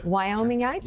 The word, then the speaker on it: Wyomingite: Wyomingite? – Sure.